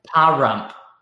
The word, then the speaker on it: Pahrump: And I'm trying to figure out how the hell you pronounce this city. Parump? – I know. There's a reason I skipped it. There's a reason I went right to Nevada and skipped over the town name. Pahrump. P-A-H-R-U-M-P.